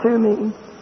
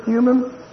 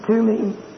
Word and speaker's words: Thummim: As a picture, they were to be under subjection to these two mysterious stones that God put inside, called Urim and Thummim. Those are the Hebrew pronunciations. Some folk pronounce them with English pronunciations of Urim and Thummim, but actually they're Hebrew words, Urim and Thummim.